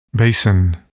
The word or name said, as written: basin